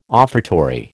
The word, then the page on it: offertory: American English